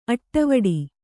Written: ಅಡಿವಿಡಿ

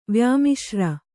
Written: ವ್ಯಾಮಿಶ್ರ

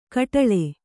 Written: ಕಟ್ಟೋಲೆ

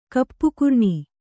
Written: ಕಪ್ಪುಕರ್ಣಿ

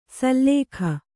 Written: ಸಲ್ಲೇಖ

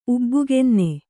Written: ಉಬ್ಬುಗೆನ್ನೆ

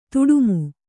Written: ದುಡುಮಿ

